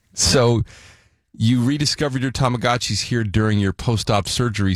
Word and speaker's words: Tamagotchis: Tamagotchis.